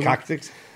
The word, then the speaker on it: coccyx: Coccyx? – Yeah. – Coccyx? Did I say it right? That's right. You got it right.